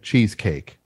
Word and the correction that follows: Cheesequake: Is that how you say it? Cheesecake? – Cheesequake. – Cheesequake.